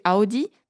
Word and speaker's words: Audi: Après, il y a aussi Audi, que l'on devrait normalement prononcer Audi, mais encore Opel ou Porsche.